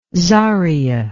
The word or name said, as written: zaria